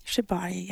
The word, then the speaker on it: Shibari: So I would love to start the practice of, and I don't know if I even pronounce this correct, Shibari. – Shibari, yes.